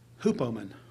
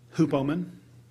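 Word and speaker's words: hupomone: It's H-U-P-O-M-O-N-E, with a little line above the E. The pronunciation told me it was hupomen. Hupomen. I may have butchered that.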